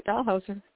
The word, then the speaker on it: Dahlhauser: That's right, Dalhauser.